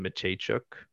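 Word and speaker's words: Mateychuk: And then re-signed their two first rounders, David Juracek and Denton Mechaychuk.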